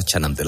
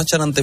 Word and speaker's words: Chanante: Bueno, me encanta cómo pronuncié el CH los andaluces. Le dais un matiz muy bonito, ¿verdad? – Porque, hombre, pero Chanante. – Chanante, sí, pero es una CH todavía con más.